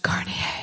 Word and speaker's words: Garnier: The MP in question is called Mark Garnier, a name that I happen to know from watching too much television should actually be pronounced... – Garnier.